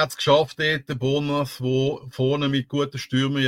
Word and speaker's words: Bournemouth: Also ausgesprochen Bomov.